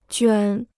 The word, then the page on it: 卷: juàn